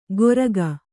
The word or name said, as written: ಗೊರಗ